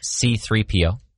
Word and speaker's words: C-3PO: We have 3CPO. I know that one. – Nope. C-3PO. – You flipped that around. I flipped it around.